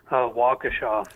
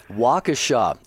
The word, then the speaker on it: Waukesha: We go to Waukesha, Wisconsin. If I said that right, you're going to have to help me out on that. Is it Waukesha, Wisconsin? – Waukesha. – Waukesha. Okay.